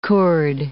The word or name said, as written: kurd